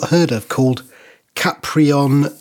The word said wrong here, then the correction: Caproni: FM. – Caproni FM. It's like, thank you. I tell you, my pronunciation this week, I've learned so much. – Yeah, it's like pasta, except it's not pasta because I think it's Spanish.